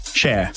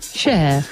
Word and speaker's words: Cher: Cher. – Cher.